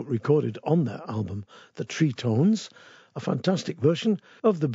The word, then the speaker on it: Trí-Tones: They've called themselves the Tritones, not the Tritones. The Tritones are another group entirely. I think they're like a rockabilly band. Tritones is spelt T-R-I Fada. It's got a little accent on it.